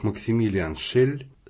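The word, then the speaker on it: Maximilian Schell: Про Максимилиана Шелла, имя и фамилию которого в германоязычных странах произносят как Максимилиан Шель, следует прежде всего сказать, что он имеет счастье жить свою собственную, ни на кого не похожую жизнь.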